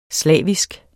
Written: slavisk

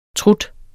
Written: trut

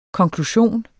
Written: konklusion

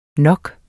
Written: nok

